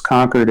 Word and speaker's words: Concord: Which is sometimes pronounced Concord in other parts of the world, but it's Concord in Massachusetts.